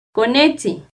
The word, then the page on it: conetzin: Náhuatl